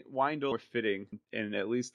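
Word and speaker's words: Wyndle: I know I'm wrong about everything, but I say his name is Windel. – I think that's actually how it is. I was just going along with what Dave said. – Yeah, it's Windel.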